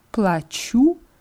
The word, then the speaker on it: плачу: Я плачу heißt ich zahle. – Я плачу. – Und hier ist die Betonung auf dem U sehr, sehr wichtig, weil ja плачу mit Betonung auf A heißt ich weine.